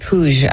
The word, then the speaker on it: Puig: Oh, so that's how you pronounce it, Puig.